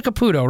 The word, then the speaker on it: Caputo: Am I properly saying your name right? Because I never know that. It's Teresa Caputo, right? – You're saying it perfectly.